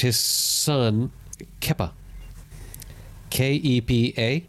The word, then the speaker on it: Kepa: If you see the letters K-E-P-A, you're going to say Kepa.